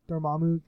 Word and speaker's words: Dormammu: So, Dormammu. Is that how you say it? – Dormammu.